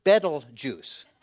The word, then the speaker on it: Betelgeuse: And this is pronounced Betelgeuse.